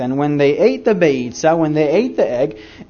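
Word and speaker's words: Beitzah: The fourth item that you find on a Seder plate, I'll spell it for you. B as in boy, E-I-T-Z-A-H. Beitza. Not pizza, Jim, but beitza.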